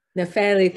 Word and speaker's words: Nefeli: Nefeli.